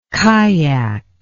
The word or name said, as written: kaiak